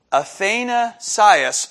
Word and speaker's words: Athanasius: Um, Athena, oh man, I can never pronounce his name properly. Athanasius of Alexandria, Augustine, and Jerome all acknowledged in letters to churches the 27 books of the New Testament.